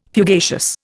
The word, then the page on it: fugacious: Indian